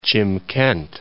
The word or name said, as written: Chimkent